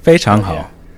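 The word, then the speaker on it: Fei Chang Hao (非常好): Fei Chang Hao. That was the best pronunciation.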